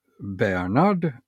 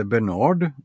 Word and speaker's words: Bernard: Och i lucka 23 så hittar vi en Bernard, eller Bernard, beroende på hur man vill uttala det.